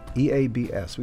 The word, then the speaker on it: EABS: Prior to that, EABS. That is how the name is pronounced. It's not pronounced as a one-syllable name. Stands for, I think, Electroacoustic Beat Sessions. EABS.